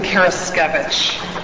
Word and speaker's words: Karaskevich: My name is Susan Karaskevich. Phonetically, it is a little difficult to pronounce. Looks like a carrot sandwich, my sisters always tell my nieces and nephews. That's how they pronounce my name.